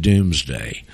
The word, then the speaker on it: Domesday: And it's spelled D-O-M-E, Domesday, but we pronounce it Doomsday.